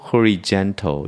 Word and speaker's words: horizontal: 호라이즌이라고 읽지 않고 horizontal 이렇게 읽는 겁니다.